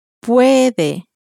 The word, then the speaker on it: puede: Puede. Puede. – As I said a moment ago, words that end in a vowel in Spanish are stressed on the next to last syllable, on the penultimate syllable.